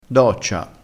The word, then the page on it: doccia: dialettale